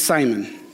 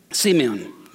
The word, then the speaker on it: Simeon: Everybody says Simon. Simeon. Is that how you say it? Simeon? Simeon.